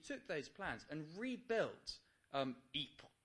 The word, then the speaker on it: Ypres: You know, I remember reading about this Belgium town, which I can't pronounce, but it's Ypres. Is that right? Y-P-R-E-S? Ypres. Ypres. Okay, well, there we go.